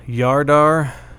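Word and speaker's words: Jardar: I assume they pronounce the J like a Y. Yardar. Because it's J-A-R-D-A-R.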